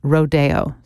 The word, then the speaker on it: Rodeo: Now, the composer titled this ballet Rodeo, but in ballet circles, you'll definitely hear it pronounced Rodeo. So however you pronounce it,